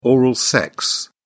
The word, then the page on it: oral sex: British English